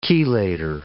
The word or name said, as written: chelator